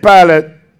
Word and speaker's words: Pilate: Then Pilate, and they don't spell the same, but you know, that's how it's pronounced, Pilate. Then Pilate went back into his headquarters and called for Jesus to be brought to him.